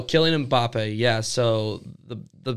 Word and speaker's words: Kylian Mbappe: Kylian Mbappe.